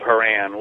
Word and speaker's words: Haran: And only later did archaeologists actually confirm, there was a city called Haran, which, rhymes with caravan because it was a caravan city.